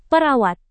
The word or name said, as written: perawat